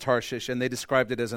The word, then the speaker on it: Tarshish: Tarshish, which makes you sound stupid no matter how you say it, I am saying it correctly, I promise.